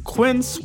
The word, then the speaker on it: Quince: Today Explains support today comes from Quince, which rhymes with since but is spelt with a Q-U.